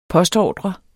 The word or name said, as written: postordre